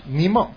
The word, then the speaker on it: Nemo: El Nemo. – El Nemo, ajá. – ¿Nimo? – Ajá, es que así se pronuncia en inglés.